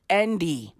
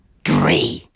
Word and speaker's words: Andry: A-N-D-R-Y. His surname is Rajoelina. R-A-J-O-E-L-I-N-A. Andre Rajoelina. Not Andy. Not Andrew. No. Andre. Dre. Or Andre. Rajoelina.